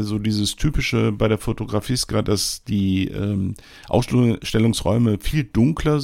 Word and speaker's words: Fotografiska: Die habe ich natürlich, die Zeit hatte ich mir genommen, anzuschauen in der Fotografiska. Bisher habe ich immer Fotografiska gesagt und ich weiß jetzt, dass ich das immer falsch ausgesprochen habe. Das wird Fotografiska ausgesprochen, Entschuldigung. So, ja, 15 Euro, das ist dann auch ein Wort, also auch vielleicht ein kleines bisschen teurer als die eine oder andere Ausstellung.